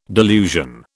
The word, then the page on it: delusion: British English